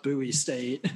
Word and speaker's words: Bowie State: It's actually pronounced Bowie State. There's some confusion, but yeah, it's like, Bowie State.